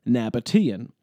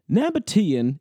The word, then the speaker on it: Nabataean: Nabataean is spelled N-A-B-A-T-E-A-N. And because of this E-A-N ending, my friend was unsure if he should pronounce it Nabataean, as you might expect given the usual pronunciation of the E-A vowel combination, or Nabataean.